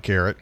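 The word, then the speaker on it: Laura Karet: Yeah, I want to thank Laura Carrot for joining us. It's not Carrot, it's Carrot. Sorry, Laura. I knew I would do it because it's spelled K-A-R-E-T like the ring, and I even made a joke about it.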